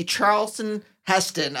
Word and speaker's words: Charlton Heston: I am like the Charlton Heston of grudges.